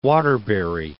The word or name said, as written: Waterbury